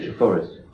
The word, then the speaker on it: Treforest: So what have you been up to this week that the people here in near Cardiff in Treforest might like to try? – Is that how you pronounce it, Treforest? – Treforest.